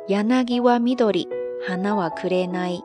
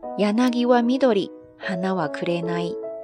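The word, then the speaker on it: 柳は緑花は紅: 非常的简单，汉字写作柳绿花红，读作 yanagi wa midori hana wa kurenai。 yanagi wa midori hana wa kurenai。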